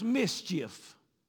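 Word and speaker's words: MSCHF: Now listen, capital M, capital S, capital C, capital H, capital F. It's pronounced mischief.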